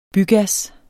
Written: bygas

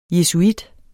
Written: jesuit

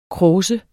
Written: kråse

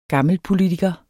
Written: gammelpolitiker

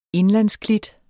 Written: indlandsklit

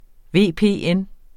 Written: VPN

